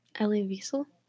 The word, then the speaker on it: Elie Wiesel: Elie Wiesel.